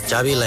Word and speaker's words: Xavi: So I heard it. Xavi like chocolate.